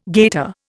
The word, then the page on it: gaiter: Indian